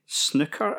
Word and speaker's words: Sinuca: Oh, so it's spelled Sinuca Attack, but it sort of sounds like Snooker Attack, okay?